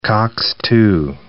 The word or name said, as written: COX-2 inhibitor